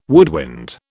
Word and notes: woodwind: British English

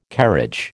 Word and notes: carriage: American English

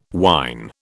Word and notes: wine: British English

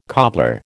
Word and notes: cobbler: American English

